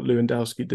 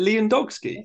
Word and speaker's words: Lewandowski: Was it Lewandowski? – I said Lewandowski. – Lewandowski. – You said Lou rather than Lev.